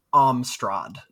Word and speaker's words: Amstrad: Amstrad.